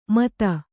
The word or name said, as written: ಮತ